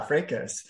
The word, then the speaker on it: fracas: Fracas, yeah, fracas. – You pronounce it fracas? – It's a fracas, yeah.